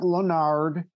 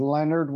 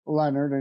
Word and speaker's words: Lenard: Yeah, you said the name right. It looks like Lenard, but it is not. It is Lenard without an O, Joseph M. Lenard, and the middle initial is important because there is a Joseph Lenard in North Carolina that's also an author.